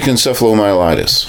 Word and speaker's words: encephalomyelitis: Myalgic encephalomyelitis.